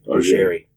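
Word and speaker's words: Augeri: Augery. It rhymes with Steve Perry.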